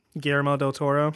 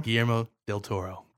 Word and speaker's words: Guillermo del Toro: Guillermo del Toro. – Guillermo del Toro.